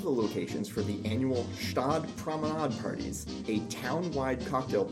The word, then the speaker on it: Gstaad: Julie Andrews owns a chalet in Gstaad, Switzerland. – I'm pretty sure you don't pronounce the G in Gstaad.